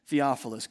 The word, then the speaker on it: Theophilus: And Luke sets out to write an orderly account, as he puts it, of the gospel to the most excellent Theophilus.